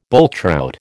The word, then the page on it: bulltrout: American English